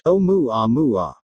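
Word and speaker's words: Oumuamua (ʻOumuamua): Earlier this year, researchers at Arizona State University published a new study claiming to resolve the mystery surrounding Oumuamua, pronounced Oumuamua.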